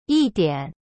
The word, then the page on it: 一点: yi dian